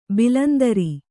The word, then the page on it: ಭಾಳಾಂತರ: bhāḷāntara